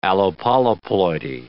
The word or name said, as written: allopolyploidy